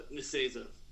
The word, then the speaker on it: Nicieza: Yeah, I remember reading a lot when Fabian Nicieza, or whatever you say his name is. – Yeah, Nicieza.